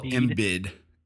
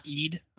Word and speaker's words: Embiid: Whether, and then who knows the year after that if Embiid, we need to learn how to say his name. – It's Joel Embiid. – Embiid? No, yeah. Not Eid? Okay. – Nope. Embiid. – There you go.